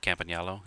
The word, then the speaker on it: Campagnolo: And then you get mag wheels by Campagnolo. Is that how you say it? – Campagnolo, yeah.